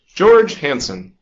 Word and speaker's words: George Hansen: George Hansen.